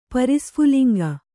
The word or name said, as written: ಪರಿಸ್ಫುಲಿಂಗ